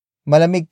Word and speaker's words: malamig: Malamig kahapon hindi ba?